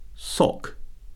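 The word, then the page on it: sock: noun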